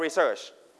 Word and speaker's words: research: research If it is a noun, we set on the first syllable, we call research.